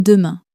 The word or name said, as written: demain